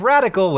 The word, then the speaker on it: Radica: Like radical without the L, Radica."